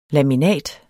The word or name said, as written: LAMINAT